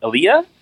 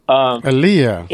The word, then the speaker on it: Aleia: Alia, I think it's pronounced, in Barcelona. – Alia. – A-L-E-I-A.